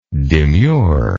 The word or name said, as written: demureness